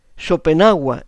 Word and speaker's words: Schopenhauer: Arthur Schopenhauer, Schopenhauer en la pronunciación alemana,